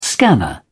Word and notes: scanner: British English

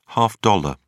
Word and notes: half dollar: British English

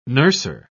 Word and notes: nurser: British English

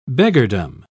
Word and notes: beggardom: British English

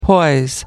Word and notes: poise: American English

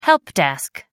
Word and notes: helpdesk: American English

